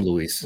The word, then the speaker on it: Luis: Just anyway, call him Luis. Luis me. I got it right.